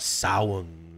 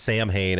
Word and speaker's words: Samhain: You know, he's talking about Samhain or whatever. That's how, you know, everybody pronounces it Samhain, and I think he says Samhain, or I guess that's the correct pronunciation, but...